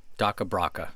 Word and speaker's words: DakhaBrakha: Daka Braka, spell it. – D-A-K-H-A. B-R-A-K-H-A. – Daka Braka.